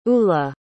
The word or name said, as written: ula